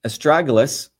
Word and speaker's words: astragalus: So again, as I started off, astragalus, that's the way you pronounce this, is known as a guardian root.